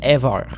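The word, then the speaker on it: Eivør: Ça s'écrit E-I-V-O-O avec une petite barre R, mais ça a l'air qu'il faut dire Ever.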